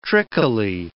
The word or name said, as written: trickily